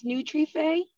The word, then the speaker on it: Nutrife: It's pronounced Nutrife. Um, okay, okay. It came from I'm Latina, uh, and in Spanish, fe means uh, faith. So it's nutrition faith. Okay. So faith in nutrition.